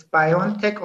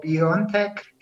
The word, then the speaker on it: BioNTech: You can pronounce it as BioNTech or BioNTech. It is we did not dictate how, how the company should be uh, pronunciated.